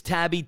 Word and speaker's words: Taibbi: Matt, M-A-T-T, I think it's Tabby, T-A-I-B-B-I is how you pronounce that cat's last name.